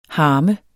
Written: harme

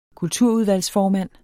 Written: kulturudvalgsformand

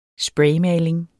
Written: spraymaling

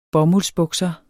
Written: bomuldsbukser

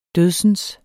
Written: dødsens

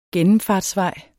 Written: gennemfartsvej

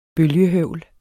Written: bølgehøvl

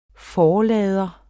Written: forlader

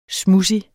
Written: smudsig

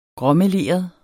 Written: gråmeleret

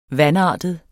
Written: vanartet

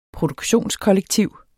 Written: produktionskollektiv

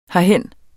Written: herhen

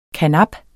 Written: karnap

